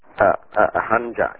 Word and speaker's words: Hanja: Is Hanja K-A-N-J-A? – Hanja, H-A-N-J-A. I'm Hanja. Yeah. – Yes. – Spell it again, please. – H-A-N-J-A.